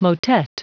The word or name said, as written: motet